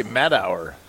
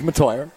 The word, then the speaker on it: Matteuer: It's spelled M-A-T-T-E-U-E-R. – I would say Matauer. – It is Matoyer.